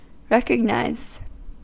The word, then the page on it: recognise: American English